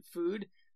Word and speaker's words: Fud: You know that there's a brand of hot dogs you can get from Mexico, and they're called Food, but it's spelled F-U-D, so it's like...